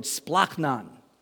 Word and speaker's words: splachnon: It's actually Greek, but it sounds like Klingon. It's the word splachnon. Splachnon means this right here: your gut, your bowels.